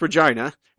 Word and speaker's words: Regina: The drive, it was Orlando, then Tennessee, then Minneapolis, then Regina, Saskatchewan, and it is pronounced Regina.